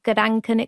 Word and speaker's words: Gedanken: They're called Gedanken experiments. I think I've said that correctly, Brian. – You did, absolutely.